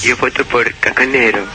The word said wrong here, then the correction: Cacaseno: Por Cacaseno. – Cacaseno, pronuncia bonito.